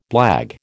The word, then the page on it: blague: American English